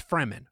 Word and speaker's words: Fremen: Sure, there are Nazis who can speak a word and kill you like a fucking Freeman. Oh, no. – No. – It's Fremen. Fremen. I actually gave you the pronunciation of Fremen.